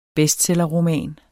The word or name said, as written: bestsellerroman